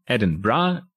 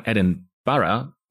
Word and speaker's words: Edinburgh: weil zumindest aus meiner Perspektive sagen Briten Edinburgh und äh, Schotten sagen Edinburgh oder eher mit einem sehr harten rollenden R zwischen den beiden A's, also Edinburgh, ohne dass ich es jetzt besonders gut nachmachen könnte.